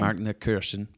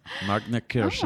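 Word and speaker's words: Markneukirchen: Oh, is that how you say it? – Mm-hmm. That's how the Germans say it. – Oh, and, say that again. That was good. – Markneckirchen. Markneckirchen.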